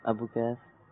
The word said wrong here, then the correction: ابو قبیس: قبیس. تھوڑی تلفظ کی مسٹیک ہو رہی ہے. صحیح تلفظ سے بتا دیں اگر تو تحفہ مل جائے گا. – جبل ابو قبیس. – جبل ابو قبیس جواب درست ہے.